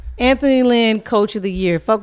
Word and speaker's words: Lynn: Might be Lynn. – Yes, it is. It is. Linn.